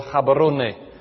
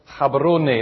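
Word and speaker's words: Gaborone: It was then just a small town called Habarone. You'll notice it's spelt with a G, but we say H. We never say G in Setswana. So it's Habarone.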